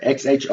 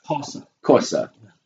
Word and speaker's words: Xhosa: Xhosa. Mm-hmm. But the correct pronunciation is... Khoisa. You got to do the little click, you know, the little clicks you hear. Yeah. – Khoisa.